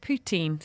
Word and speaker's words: poutine: Um, they say it's pronounced poutine, so that's what it is.